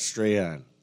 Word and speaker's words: Strahan: Because it's spelled like Strawn. – No, that's Strahan. You know, how I learned. Well, he calls himself Strahan.